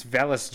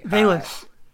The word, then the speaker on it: Velus: But uh, it's it's Velus Jones uh, to me. – Velus. – Uh, okay, Velus.